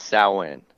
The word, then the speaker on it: Samhain: Well, I mean, according to Glenn, it's Samhain. – Yeah. The people that I know that are involved in like the neo-pagan world or Wicca and all that, so that they say Samhain, you know, and they refer to it, but me and you, yeah, I'm just a suburban punk, so I call it Samhain.